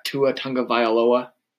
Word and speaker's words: Tua Tagovailoa: I got Tua Tagovailoa. Is that it? – Yep, yeah, that's how you pronounce it. I'm called Tua Tagovailoa.